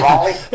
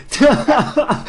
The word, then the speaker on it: Raleigh: All right, well, say hello to everyone in Raleigh for me, mate. – Will do. – I said that right, didn't I? – Yeah, Raleigh. It's close. Yeah, yeah. Either one is all right. – Raleigh.